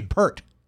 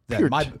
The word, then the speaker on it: Peart: Yeah, P-E-A-R-T. That's the same spelling, isn't it? – Yeah. – So it'd be maybe Pert. – Peart. – My bad. – Peart. – But it was Neil. Didn't Neil Peart want to be called Neil Peart and not Neil Pert? – I don't know. I don't know. – Even though the name was spelled like Pearl, but right. – It was really Peart.